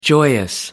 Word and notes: joyous: American English

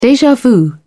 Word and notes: deja vu: British English

